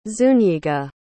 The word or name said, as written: zuñiga